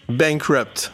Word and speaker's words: Bankrupt: Il faut le prononcer à l'anglaise. Hein. Donc bah. ça se dit bankrupt. – Bankrupt, ok, bankrupt.